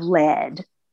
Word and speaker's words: lead: So okay, we have the two LEADs, and one is pronounced lead with a long E, and the other one, the metal, is pronounced with a short E, led.